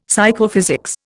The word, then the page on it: Psychophysics: Indian